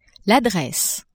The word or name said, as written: adresse